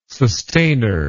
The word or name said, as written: sustainer